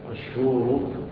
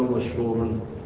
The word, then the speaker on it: مشهور: قال مشهور بالضم دون تنوين، مشهور ولا يقال مشهورا مراعاة للنظم أي للوزن والبحر.